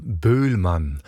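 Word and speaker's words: Boëllmann: Het is het introduction en het menuet uit de suite gothique van Léon Boulman. Oftewel, hoe ik een Fransman ooit hoorde uitspreken, Léon Boulman.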